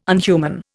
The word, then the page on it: unhuman: Indian